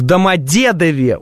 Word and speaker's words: Домодедове: В Домодедове ударение на середину, в Перове, говорит топ.